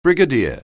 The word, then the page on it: brigadier: anglais